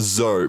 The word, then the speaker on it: Doz: So, it's pronounced Zo, but it's spelled D-O-Z. D-O-Z. – Okay. – D-O-Z. Zo. – Zo. It's French for some reason. – Yes, French. Zo. Has French origins. – Is it... D-O-Z, but why would that?